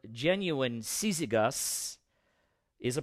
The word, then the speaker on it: Syzygus: So pronounce something like Syzygus. Syzygus.